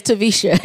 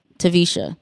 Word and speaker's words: Taversha: My name is Tavisha, and it's spelled T-A-V as in Victor, E-R-S-H-A. The R is silent. Tavisha.